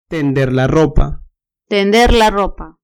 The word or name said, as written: tender la ropa